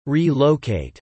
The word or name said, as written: Re-locate